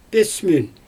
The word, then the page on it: pIsmün: Xyzyl